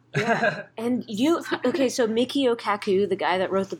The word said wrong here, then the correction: Michio Kaku: I don't know if I'm saying his name right. Kaku? – Michio Kaku.